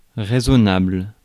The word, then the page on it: prudent: American English; /ˈpɹuːdənt/